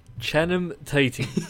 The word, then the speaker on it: Channing Tatum: It is Channing Tatum. – Aha! Channing Tatum. Who the fuck is Channing? I don't know who Channing Tatum is.